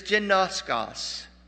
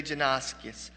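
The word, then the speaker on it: genoskos (γινώσκω): But it is genoskos, is the way you would pronounce that. Some would say genoskis.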